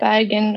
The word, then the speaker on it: Bergen: Okay, so you were born in Bergen, Norway? – Yes. Is that how you say it? Bergen, Norway, yes.